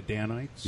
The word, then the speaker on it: Danites: I'm saying that right, aren't I? The Danites? Yep. Yeah. yep.